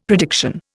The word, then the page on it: prediction: Indian